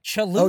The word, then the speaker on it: Cholula: Are you saying Cholula? – Yeah, could you try that again? – Well, how do you say it? – Cholula. – Oh, Cholula. Oh, you're right. Cholula. Yeah, I skipped an L there. – You're saying it like Kalua.